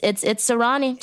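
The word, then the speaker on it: Serani: Am I pronouncing that right? – Yeah, it's Serrani, just in case you never know.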